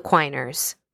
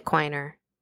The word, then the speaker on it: Quiner: Also, that is how the last name is pronounced. I checked with John Bass, president, director, and historian of the Ingalls Wilder Lane Historic Alliance, located in Shreveport, Louisiana. I also checked with a descendant of the Quiners who has the last name Quiner. He told me it rhymes with Weiner.